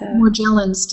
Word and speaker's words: Morgellons: Morgellons disease. – Morgellons disease is a byproduct of GM foods.